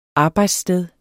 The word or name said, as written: arbejdssted